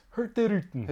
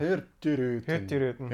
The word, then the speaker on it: Hurtigruten: I want to defer to our linguistics expert here because it's pronounced Hurtigruten, right? So yeah. The G is silent. – Hurtigruten. – Hurtigruten.